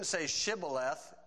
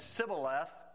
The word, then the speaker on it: Shibboleth: Okay, so they evidently had couldn't say Shibboleth, and he said Sibboleth. So that's how they say it.